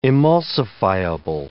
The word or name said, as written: emulsifiable